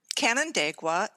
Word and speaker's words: Canandaigua: Canandaigua? How do you pronounce it? – Canandaigua, and I believe it's Seneca Indian, Native American, and it means the chosen spot.